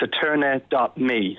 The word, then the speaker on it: Eterni.me: It's kind of like a play on Eternity, so it's Eterni.me.